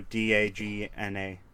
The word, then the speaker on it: Dagna: The name is spelled Dagna. – Dagna. Dagan? – No, Dagna.